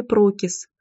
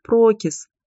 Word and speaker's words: Прокис: Маг по имени Прокис. Не поняла тебя. Маг Прокис? Засмеялась Лилит. Мамочка, нет, ударение на первый слог. Прокис. а не прокис.